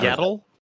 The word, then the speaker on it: Yaddle: And then April reads the instructions on the throwing star about the legend of Yaddle, who up until this point, I'm like, was that the female Yoda? How do you say her name? – Yeah. – Yaddle? – Is that Yaddle? – Y-A-D-D-L-E.